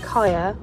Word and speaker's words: Kaya: My mom decided to call me Kaya, spells K-A-Y-A, after one of Bob Marley's songs.